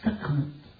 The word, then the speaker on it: Sekhmet: S-E-K-H-M-E-T. And so I try to pronounce all those as they're really there. I could take the H out because in Spanish and several languages, the H is silent. So we could say it, Sekhmet.